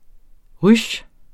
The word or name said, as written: ruche